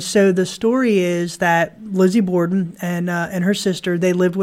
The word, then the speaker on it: Lizzie Borden: Lizzie Borden. – The Lizzie Borden house. – Or yep. Borden house. – Yeah, Lizzie Borden.